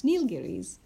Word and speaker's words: Nilgiris: Nilgiris is another mountain range which the boys refer to in the novel. And that's how you pronounce Nilgiris.